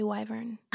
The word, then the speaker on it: wyvern: It is actually wyvern.